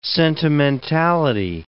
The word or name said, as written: sentimentality